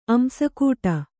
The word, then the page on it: ಅಂಸಕೂಟ: kannada